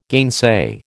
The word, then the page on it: gainsay: American English